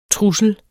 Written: TRUSLER

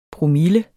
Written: promille

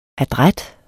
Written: adræt

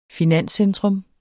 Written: finanscentrum

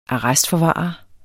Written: ARRESTFORVARERNES

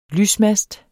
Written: lysmast

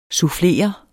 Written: sufflere

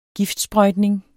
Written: giftsprøjtning

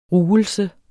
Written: ruelse

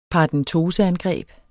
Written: paradentoseangreb

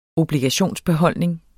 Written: obligationsbeholdning

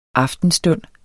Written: aftenstund

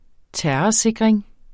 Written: terrorsikring

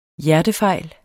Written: hjertefejl

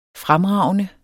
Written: fremragende

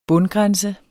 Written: bundgrænse

